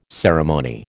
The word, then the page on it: ceremony: American English